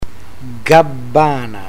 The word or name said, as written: gabbana